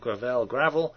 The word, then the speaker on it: Gravel: Mike Gravel, how do you say that? Gravel, thinks that nuclear war is immoral.